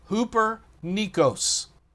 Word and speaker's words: hupernikos: Hoopernikos.